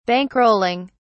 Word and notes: bankrolling: British English

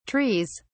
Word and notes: trees: British English

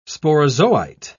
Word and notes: sporozoite: British English

